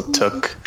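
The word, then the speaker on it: Tûk: It's a circumflex. That just means it's a long vowel sound, so it's basically two O's. So it's still Tuk.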